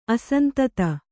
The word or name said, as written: ಅಸಂತತ